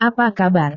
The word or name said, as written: apa kabar